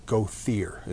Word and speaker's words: Gothier: One thing, like one prominent name on that side of the family is a name, Gothier is how we pronounce it here. G-O-T-H-I-E-R, right? Properly in French, it would be Gothier.